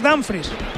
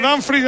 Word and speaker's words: Dumfries: Lo digo bien, Dumfries. – Tengo dudas en esa ¿cómo es exactamente? – Yo creo que es Dumfries.